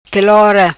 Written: quelora

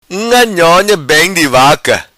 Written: vaca unga nhonha

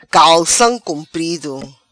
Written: Calcam Cumprido

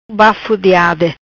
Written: Bafo de ade

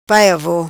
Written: pai avo

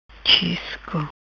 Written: Chisco

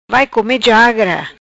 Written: Jagra vai come

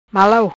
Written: Malau

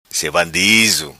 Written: Sevandizo

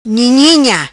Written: Nhinina

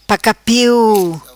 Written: pacapiu

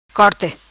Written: Corte

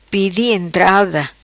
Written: Pedi Entrada

